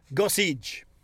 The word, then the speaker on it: Gossage: It's sort of like Gossage. – So there's a D in it? – No. No, it's not fridge, but you sort of say it like it's Tim Gossage.